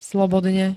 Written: slobodne

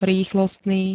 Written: rýchlostný

